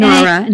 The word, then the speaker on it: NARHA: You belong to the NARHA, did I say that right? – NARHA. – NARHA, N-A-R-H-A, the North American Riding for Handicapped Association.